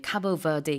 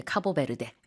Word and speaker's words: Cabo Verde: 영어로는요, Cape Verde, Cape Verde, 뭐 굉장히 다양하게 하고 아니면은 이제 원어랑 비슷하게 Cabo Verde 이렇게 말하기도 하는데 다 됩니다. 우리는 이제 카보베르데 이렇게 얘기를 하죠.